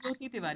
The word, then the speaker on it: Jyoti Tiwari: Jyoti Tiwari.